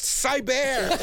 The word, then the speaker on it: Siebert: Fred Siebert. – I keep wanting to call him Siegel. – I'm going to get 9,000 Twitter messages. It's pronounced Cyber.